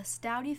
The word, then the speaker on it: Staudte: That's S-T-A-U-D-T-E, pronounced Staudte.